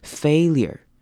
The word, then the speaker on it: failure: Failure 这个字是这句子比较难念一点的，那它前面是长母音 A 的声音 failure，那后面 URE 比较特别，我们会念成 ear 的声音 failure。